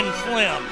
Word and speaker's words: phlegm: Why is there a G in it? Why do we need a silent G in phlegm? My God, phlegm won.